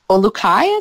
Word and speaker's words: Olukai: Is it Olukai? – Olukai is what I would say. – Okay, O-L-U-K-A-I, Olukai, and just purchase their shoes.